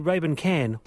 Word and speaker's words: Raybon Kan: So I call you Raybon Can. What should I be calling you? – Well, that's one of the options. That's how I say it sometimes.